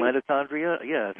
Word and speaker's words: mitochondria: If you're just tracing, how do you pronounce it? Mitochondria, did I pronounce that right? – Mitochondria, yes.